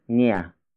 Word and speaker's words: niya: On its own, anak is genderless, after which it's niya. Niya. Niya. Niya is spelled as N-I-Y-A.